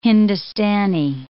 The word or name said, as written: Hindustani